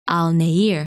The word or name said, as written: Al Nair